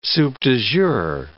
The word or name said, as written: soup du jour